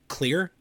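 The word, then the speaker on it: Xlear: Not a sponsor, but it's just called Clear. I think is how it's pronounced, but it's spelled with an X. So it's X-L-E-A-R.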